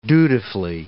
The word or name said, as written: dutifully